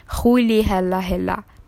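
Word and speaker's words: Rolihlahla: رولی هلا هلا یا به تلفظ خودشان خولی هلا هلا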